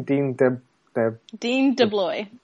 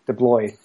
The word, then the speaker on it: Dean DeBlois: Dean DeBlois. – DeBlois.